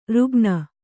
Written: ರುಗ್ಣ

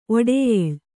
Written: ಒಡೆಯಳು

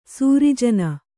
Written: ಸೃಜನ

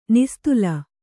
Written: ನಿಸ್ತಲ

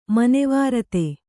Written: ಮನೋವಿರತಿ

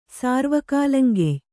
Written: ಸಾರ್ವಕಾಲಂಗೆಯ್

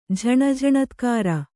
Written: ಝಣಝಣತ್ಕಾರ

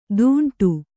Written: ದುಂಡು

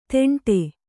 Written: ತೆಂಟೆ

